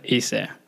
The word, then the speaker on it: Ciise: So it's Abdul Qadir Muhammad Ciise.